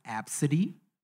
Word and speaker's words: Abcde: So the name reads A, B, C, D, E, their last name. And she's like cracking up about this like name. The name is pronounced Absidy.